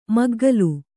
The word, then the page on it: ಮಗ್ಗಲು: maggalu